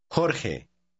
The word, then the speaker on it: Jorge: The letter J is pronounced the same way. Read word 9. – Lejos. Lejos. – 10. – Juan. – Try word 11. It's the name George. – Jorge.